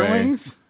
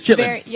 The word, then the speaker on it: chitterlings: Chitterlings? – Well, I would say chitlins, but I would... – It's chitlins. – I know it's chitlins, but it's chitterlings.